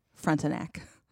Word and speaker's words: Frontenac: I think that is the most proper pronunciation that I could possibly give with my terrible French accent. From now on, it will be Frontenac.